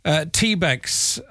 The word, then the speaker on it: TBEX: It is the TBEX. I presume there's no other way to pronounce that. Is there Rick? Rick Calvert, CEO of TBEX? – You got it right.